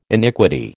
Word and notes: iniquity: American English

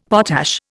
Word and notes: potash: Indian

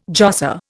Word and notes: josser: Indian

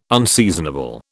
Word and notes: unseasonable: British English

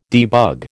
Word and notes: debug: American English

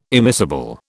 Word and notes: immiscible: British English

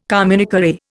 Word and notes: communiqué: Indian

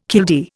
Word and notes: killdeer: Indian

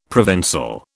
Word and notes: Provençal: British English

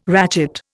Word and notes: ratchet: Indian

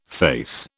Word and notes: face: British English

